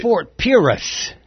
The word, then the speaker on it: Fort Pierce: It's Fort Pierce.